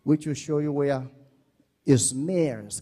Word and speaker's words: Izmir: Now, today, as I'm speaking, when you look at SMYRNA, it's now called Izmir. That's how they pronounce it in Turkey.